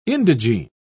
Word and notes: indigene: British English

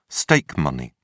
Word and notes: stake money: British English

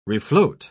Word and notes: refloat: British English

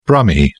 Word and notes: brummie: British English

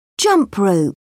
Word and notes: jump rope: British English

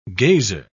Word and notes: gazer: British English

